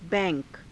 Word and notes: bai^ka: hindi

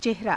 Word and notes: ceharaa: hindi

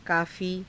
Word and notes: kaafii: Hindi

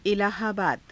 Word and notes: इलाहाबाद: ilaahaabaada

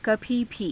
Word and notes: kabhii bhii: Hindi

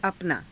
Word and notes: apanaa: Hindi

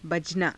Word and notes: bajanaa: hindi

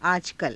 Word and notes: aajakala: Hindi

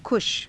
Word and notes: kusha: Hindi

